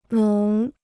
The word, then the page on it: 浓: nóng